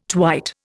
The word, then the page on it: twite: Indian